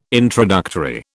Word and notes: introductory: British English

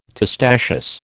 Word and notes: testaceous: American English

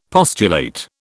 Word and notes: postulate: British English

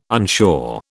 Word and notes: unsure: British English

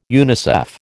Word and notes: UNICEF: American English

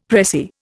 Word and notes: pressie: Indian

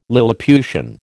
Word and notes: lilliputian: American English

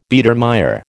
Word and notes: Biedermeier: American English

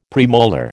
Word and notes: premolar: American English

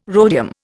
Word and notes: rhodium: Indian

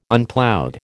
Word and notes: Unploughed: American English

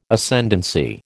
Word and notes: Ascendancy: American English

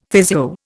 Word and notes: physio: Indian

